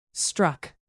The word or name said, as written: struck